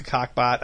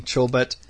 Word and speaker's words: Chobot: You know, Jones, if you were to ever meet Jessica Cockbot, I mean, Chobot, I mean, it would be hard not to pronounce her name wrong, and then you'd be, like, embarrassed.